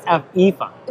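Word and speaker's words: Aoife: Efa. – Right, it's Efa, and it's A-O-I-F-E is how it's spelled. It's a traditional Irish way to spell it.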